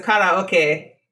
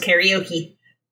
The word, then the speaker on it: karaoke: And so karaoke means empty orchestra. And then it goes back into English. It becomes karaoke, because karaoke is not a thing that English people say.